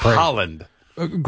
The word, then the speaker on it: Haaland: It's Haaland.